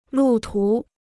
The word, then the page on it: 路途: lù tú